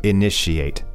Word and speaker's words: initiate: Just be careful of the pronunciation here. The spelling is initiate, but it's like a sh sound, so initiate.